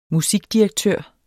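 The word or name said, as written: MUSIKDIREKTØRER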